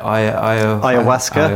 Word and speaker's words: ayahuasca: And I know that in other stages of your life you've taken, I can never pronounce it, ayahuasca.